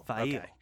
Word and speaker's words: Faile: Fail. It's pronounced Fail. with a V.